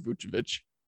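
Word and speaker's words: Vučević: Vucevic, did I say that right? – I can give you the correct term. But, yeah, former Magic power forward, Vucevic.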